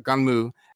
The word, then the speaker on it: Gunnm: its its name is uh, Ganmu, and it's spelled for us in English G U N N M, mm-hmm.